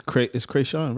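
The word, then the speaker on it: Kreayshawn: No, Krayshawn and Blueface. Why was that funny? Did I not say it correctly? – No, you said it correct.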